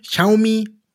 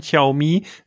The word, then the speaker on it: Xiaomi: Und jetzt kommt dann immer, wie spricht man es aus? Xiaomi, Xiaomi. Du darfst. – Wahnsinn. Ja, richtig. ich glaube, so soll sich's aussprechen. Xiaomi.